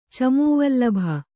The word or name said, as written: ಚಮೂವಲ್ಲಭ